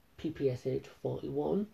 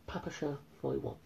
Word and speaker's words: PPSh-41: And this is where I'm going to talk about one of the weapons that I mentioned, the PPSH-41, or its proper pronunciation, the Papasha 41.